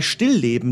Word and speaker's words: Stillleben: Also bei Stillleben liegt die Betonung mehr auf Still als auf Leben.